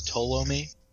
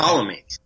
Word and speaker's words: Ptolemy: Mick the Rogue points out the pronunciation is Ptolemy. – Okay. Ptolemy.